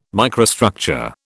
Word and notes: microstructure: British English

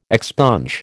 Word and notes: expunge: American English

